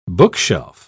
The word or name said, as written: bookshelf